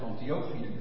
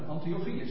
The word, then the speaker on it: Antiochië: De meeste mensen zeggen Antiochië. Ja, dat moeten zij weten, maar Antiochië, zo spreek je het eigenlijk uit.